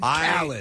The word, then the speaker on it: Khaled: How is it pronounced? – Khaled. – Because that's what I say, and somebody told me it was Khalid. I'm like, I've heard it said Khaled. – There's another artist. There's an artist named Khalid. – Khalid. Okay. – God damn it. – No, this is Khaled.